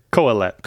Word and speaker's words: Kohelet: Help me pronounce this word. – Kohelet. – Kohelet sets out to explore the meaning of life, or teacher in Hebrew, correct? – Is that?